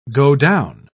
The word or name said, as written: godown